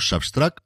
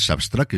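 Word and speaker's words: Subtract: pronunciado Substract, titulado con una raya, que nos dicen que hay que pronunciar Substract, es decir, restar, pero es una puñetera raya. Ya os digo yo que es una raya.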